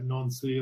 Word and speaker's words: Nancy: So he's playing in France at age 34 for it's spelled Nancy. I'm sure they say it non-C or something like that.